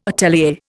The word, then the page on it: atelier: Indian